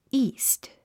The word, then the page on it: east: íːst; イースト